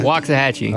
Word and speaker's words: Waxahachie: So Waxa or Waxa? – Waxahachie. – Well, I said it wrong.